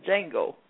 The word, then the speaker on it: Django: But or the Django, the D is not pronounced with Django.